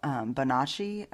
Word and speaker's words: Bonacci: The interview you just listened to is Paul Bonacci, is how I say his name, but it's B-O-N-A-C-C-I.